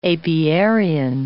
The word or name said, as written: apiarian